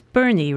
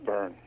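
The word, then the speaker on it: Berne: It's Bernie, right? Or is it Byrne? – No, it's Byrne.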